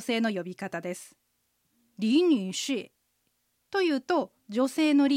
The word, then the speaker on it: 女士: 女士の女はうの口の形でにと発音します。日本語にはありませんね。で、下がって上がるアクセント、三声のアクセントです。士は舌先を歯の裏側に軽くつけたままシと発音します。アクセントは 4 声で下がります。